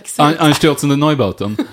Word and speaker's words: Einstürzende Neubauten: Einstürzende Neubauten.